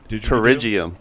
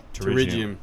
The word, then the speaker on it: pterygium: Pterygium. – Pterygium. – But it starts with a P. – Yeah. – The P is silent.